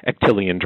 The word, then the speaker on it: Actelion: So on Thursday, the Justice Department announced that Actelion, and I wouldn't have known how to pronounce this without help from Mary Beth, but it's Actelion, like Actelion Pharmaceuticals,